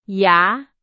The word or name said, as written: 牙